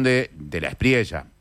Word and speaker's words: De la Espriella: de la Espriella, aplicando la forma de pronunciación correcta en este caso. De la Espriella,